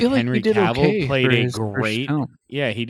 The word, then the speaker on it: Cavill: And I think Henry Cavill played a nice...